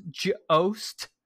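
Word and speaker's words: Jost: Pronounced Joost. – There's literally a tweet saying Minnesota's acquired Tyson Joost, pronounced Joost.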